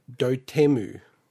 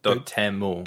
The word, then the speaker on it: Dotemu: And listening to the Nintendo Indie World, they call it Dotemu. It's like three syllables. – Dotemu. – Dotemu. – How do you spell it? – D-O-T-E-M-U. So I was reading it as dot emu.